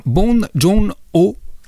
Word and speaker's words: Bong Joon-ho: Aujourd'hui, c'est le coréen Bon Jong-ho qui est mis à l'honneur. – Bonjour, bien prononcé.